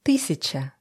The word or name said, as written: 1000